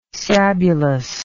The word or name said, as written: sabulous